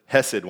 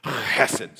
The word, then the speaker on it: chesed: They do this thing where they actually do this thing with their throat. It sounds like they're going to spit, but they're hesed love. Because I tried to pronounce it hesed one time, and they said, no, that's not how it's pronounced. It's actually hesed.